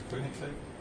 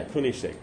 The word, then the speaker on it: Koenigsegg: Maybe it's Coins, how do you say, Coinsig? Or it's Swedish. – Koenigsegg? – Yeah, Koenigsegg.